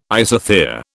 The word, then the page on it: isothere: British English